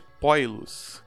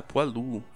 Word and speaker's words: poilu: Se fosse francês, eu acho que seria Poilu. – Poilu? – É, eu acho é, que seria algo assim. Porque foado, patê de fígado de ganso, olha aí, gordo, pensando em comida, se escreve foi e se lê foá, então eu suponho que pó e se lê poá também.